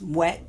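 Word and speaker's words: Moët: And my countless bottles of Veuve Clicquot and Moet, which is apparently pronounced Moet.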